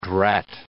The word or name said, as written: drat